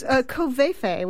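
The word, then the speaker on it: covfefe: It's covefe, which is our... I think they're pronouncing it covefe now, but it's C-O-V-F-E-F-E.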